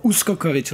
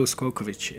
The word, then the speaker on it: Uskokovic: Well, my full name is Miroslav Uskokovic, or Uskokovic, like they would say it in Serbia. You have to accent that, right? But it's Miro Uskokovic.